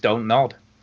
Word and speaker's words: Don't Nod: How do you say their name, the development? – Don't nod. – Is it just don't nod? Right, okay. – I mean, it's just don't nod.